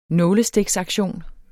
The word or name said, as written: nålestiksaktion